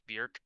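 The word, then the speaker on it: Björk: I am going to correct your pronunciation. It's Bjork, rhymes with jerk, to quote her.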